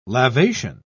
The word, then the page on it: lavation: British English